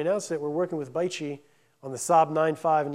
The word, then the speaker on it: BAIC: You'll see in one of these slides that Beijing Auto, or B, it's the acronym is BAIC, or it's pronounced Baichi.